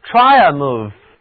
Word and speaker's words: triumph: 这个词的读音可挺奇怪的，其中的字母组合 IU 读作 IA,IA, 而字母组合 PH 发音是，来跟我读，胜利、凯旋 triumph, 它就是单词 victory 的近义词。